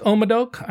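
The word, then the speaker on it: Haut-Médoc: Now, it doesn't look like Haut Médoc. I kind of looked up how to pronounce it.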